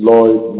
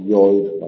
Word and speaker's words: Lloyd: Jane frequently tells her story to the apothecary, Mr. Lloyd, which is pronounced Lloyd, not Lloyd, by the way, to Helen Burns, to Miss Temple, to Rochester, and to St. John.